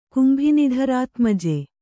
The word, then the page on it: ಕುಂಭಿನಿಧರಾತ್ಮಜೆ: kannada